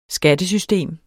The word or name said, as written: skattesystem